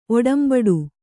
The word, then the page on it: ಒಡಂಬಡ: oḍambaḍa